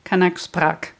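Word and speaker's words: Kanak Sprak: Oh, ich habe es falsch ausgesprochen, Entschuldigung. Kurzes A, Kanaksprach.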